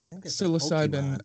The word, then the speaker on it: psilocybin: Is it like psilocybin or something like that? That's in mushrooms, like a psychedelic. – Psilocybin. – Psilocybin. Thank you.